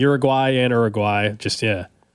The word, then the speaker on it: Uruguay: I had a Spanish teacher who made us pronounce it that way for 4 straight years in high school, and I've never been able to go back. You know, some things are just ingrained in you. Like, I mean, there's just no way I'm ever going to pronounce it any way besides that way for probably, I'm not going to say Uruguay. I'm going to Uruguay. That's how they pronounce it.